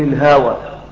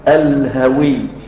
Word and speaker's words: الهوي: تقراها بعد كده تقول الهوى، هي مكتوبه كده تنطق كده، لكن هي اللفظ مشكل الهوي.